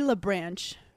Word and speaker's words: LaBranche: We have Chloe LaBranche.